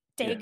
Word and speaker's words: Daegu: And the Black Traveler's Guide to, I'm going to say this wrong too, Daegu? Yeah. – No, that's right. You got it.